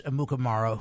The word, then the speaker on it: Amukamara: All right, so we mentioned Prince Amukamara, who... – Phenomenal pronunciation by you.